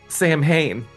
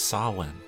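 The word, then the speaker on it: Samhain: I know this is a moment here, but I don't want to be that guy, but it's pronounced Sawin.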